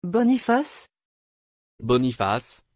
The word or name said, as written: boniface